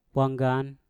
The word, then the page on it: wanggan: mpur